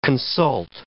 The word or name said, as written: consult